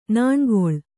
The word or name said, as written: ನಾಣ್ಗೊಳ್